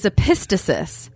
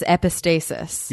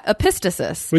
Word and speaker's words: epistasis: So the word today is epistasis, which I have always read as epistasis. – Yeah, that's how I would pronounce it in my head. – Yeah, but then I looked it up and there is no pronunciation guide anywhere on the internet that says epistasis. There's like 50 epistasis.